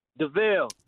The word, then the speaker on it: DeVille: DeVille. – DeVille, okay. Like a Cadillac, DeVille? – Yeah, just like that, yeah.